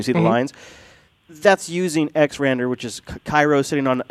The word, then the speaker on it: XRender: And then the other one is XRender, or XRender is actually how you say it out loud, not when I read it in my head.